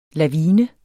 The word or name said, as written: LAVINEN